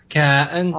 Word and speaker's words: cotton: You didn't pronounce the T in the middle. Cotton.